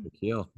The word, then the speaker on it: Coquille: Okay, so our next question is an audio question from Josh in Coquille. Is that how you pronounce it? Coquille, Oregon? Coquille, down on the south right.